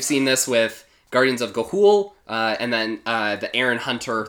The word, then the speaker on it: Ga'Hoole: No, Ga'Hoole? – Yes. – My brain just says Galooly and I move on with my life. – Yeah, the guy that makes glass over in Seattle, Ga'Hoole.